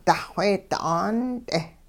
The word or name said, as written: duxweedi 'aant'e